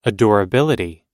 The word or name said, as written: adorability